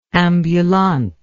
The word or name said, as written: ambulante